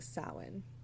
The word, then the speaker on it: Samhain: It's literally spelled like Samhain, Samhain. But its etymology and the origin of the word is it's pronounced Samhain.